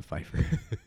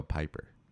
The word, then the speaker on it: Pfeiffer: Pfeiffer. – There's one additional syllable in there that's not supposed to. – Oh, yeah. I forgot. Michelle Piper. – There's no fur.